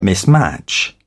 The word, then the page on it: mismatch: British English